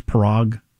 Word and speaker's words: Parag: I don't know how you pronounce his name. It's Parag.